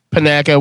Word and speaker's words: Panaka: It is Bosk versus Captain Patanka. Isn't that how you say his name? – Panaka, whatever.